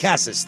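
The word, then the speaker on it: Casas: Tristan Cassis, however you say his name? – Tristan Cassis. – Cassis, thank you.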